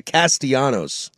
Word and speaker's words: Castellanos: By the way, is it Castellanos or is it Castellanos? – I go Llanos. – Well, if you're going to go by the proper, like, Spanish pronunciation of it, yes, it is Castellanos.